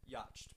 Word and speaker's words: yacht: It's just like it's spelled Y-A-C-H-T. – It's spelled yacht. – Yacht, but then you just get rid of the C-H and go yacht, right? – It's like yarmulke.